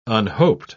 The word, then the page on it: unhoped: British English